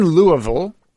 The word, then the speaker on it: Louisville: And you're currently in Louisville, as we say. – Great pronunciation.